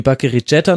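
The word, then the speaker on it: Bakery Jatta: Das ist auch so das letzte Mittel, so wie halt auch Bakary Jetta, man soll ihn übrigens Englisch aussprechen, weil er kommt aus Gambia, habe ich inzwischen recherchiert, wie Bakary Jetta nochmal reingeworfen wird,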